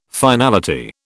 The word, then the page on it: finality: British English